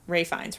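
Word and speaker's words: Ralph Fiennes: And I do I do want to point out, like, two other people that they did talk to are Robbie Coltrane and Ray Fiennes, Ray Fiennes, right? That's how you say it? – Yeah. – I can never... if I remember to say Ray and not Ralph, then I'm like, am I saying his last name wrong?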